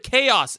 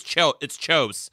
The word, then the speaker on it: chaos: I argued with this guy for like fucking years about how the word chaos is pronounced. He would say it's chose. Chose. I'm like, no, fucking is it.